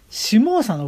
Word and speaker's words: 下総: この市原あたりがね、下総の国というところでございまして、上下の下にですね、糸辺にハムの心の聡君ので、下総と読むんですが、この下総付近でですね、朝廷に背いて反乱を起こしました。